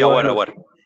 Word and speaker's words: Aouar: Y también el Aouar, creo que no sé si lo estoy pronunciando bien. También es muy buen jugador. Creo que es el jugador sí, Aouar.